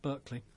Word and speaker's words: Berkeley: Berkeley, let's get the American pronunciation right here, released as a live album in 1970, and that was Hot Tuna.